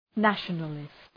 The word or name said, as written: nationalist